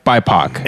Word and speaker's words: BIPOC: I was going to say, CBS is requiring a certain percentage of people in every writer's room to be biopic. – What? – Black, indigenous, people of color. – Oh, okay. – Oh, is that a biopic? – BIPOC. – It's pronounced BIPOC. Biopic. Yeah. It's like a biography.